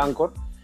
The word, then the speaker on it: Anchor: esta aplicación que se, escribe Anchor, ¿no? Este, se pronuncia Anchor.